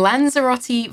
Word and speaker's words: Lanzarote: we've got a Lanzarote, is that how you say it? A Maserati volcano on a brownie. – A Lanzarote volcano on a brownie.